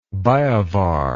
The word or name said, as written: biovar